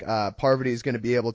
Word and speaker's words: Parvati: He says it, he literally was calling her Poverty up through the early days of her third season. – So wait, how do you say it? – Parvati, Parvi.